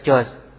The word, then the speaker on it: George: George dibaca Jos, bahasa munafik, tay, Afwan ini.